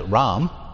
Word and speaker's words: Ram: Mem Resh becomes Resh Mem, which we would pronounce Ram.